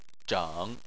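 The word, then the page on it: zheng: tone 3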